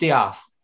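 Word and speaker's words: D'eath: Did he put an apostrophe between the E and A? – Yes, and pronounced Diath.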